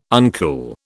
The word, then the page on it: uncool: British English